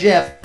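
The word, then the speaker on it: GIF: It was closed years ago at a presentation when the creator of the file format came out on stage and addressed the very question of how it's pronounced, and he said Jiff.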